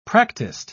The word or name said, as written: practiced